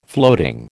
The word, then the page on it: floating: American English